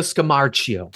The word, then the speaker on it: Scamarcio: Is Ricardo Scamarcio. Scaramouche, will you do the fandango? Man. – Thunderbolt of lightning. Very, very frightening. – Yeah, my pronunciation is very, very frightening. I'm getting... – Ricardo Scamarcio.